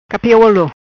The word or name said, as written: Capi olo